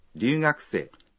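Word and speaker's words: ryūgakusei: Ryugakusei. – Es estudiante extranjero o extranjera. – Recuerden que la R inicial de ryugakusei se pronuncia como en pero, no como en perro.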